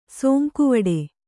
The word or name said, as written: ಸಂಕುವಿಡು